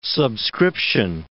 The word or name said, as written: subscription